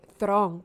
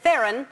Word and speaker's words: Theron: Ella fue un episodio de Ellen DeGeneres y ella le preguntó que cómo se pronuncia el apellido y ella dijo que Thron, porque ella es de Suráfrica. – Sí, sí, sí, yo sabía esa Suráfrica, ese apellido se pronuncia así.